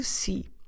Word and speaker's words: si: Eu gosto de si. Eu gosto de si. Como se escreve si? Simple. S, E, C. So again, there's nothing nasal about this word.